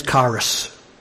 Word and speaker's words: charis: The Greek pronunciation is charis. And it's spelled, for us English people, C-H-A-R-I-S.